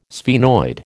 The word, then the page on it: sphenoid: American English